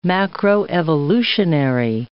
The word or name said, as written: macroevolutionary